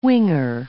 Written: winger

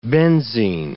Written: benzine

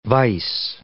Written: vise

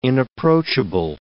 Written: inapproachable

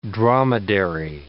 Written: dromedary

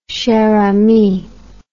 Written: chère amie

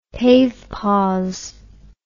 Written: PAVE PAWS

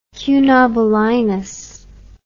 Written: Cunobelinus

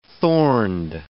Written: thorned

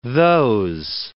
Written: those

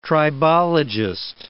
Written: tribologist

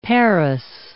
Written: parous

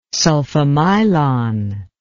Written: sulfamylon

